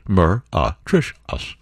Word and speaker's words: meretricious: And it's pronounced Mer-a-Trish-us.